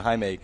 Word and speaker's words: Jaime: Everybody's like, yeah, can I get a box of them Jaime Garcias? – Yeah. – And actually, they're Latin, so they pronounce it Jaime.